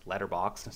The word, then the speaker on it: Letterboxd: Letterboxd, see how I say it correctly now?